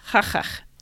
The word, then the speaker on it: qaqqaq: So like mountain is spelled Q-A-Q-Q-A-Q, and it's pronounced ha-chach.